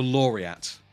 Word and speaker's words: Laureate: Is it called the Lorette? – The Laureate. The Laureate? Po- like Poet Laureate. Laureate.